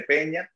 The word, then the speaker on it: Peña: Peña, así como lo estoy pronunciando, de Peña.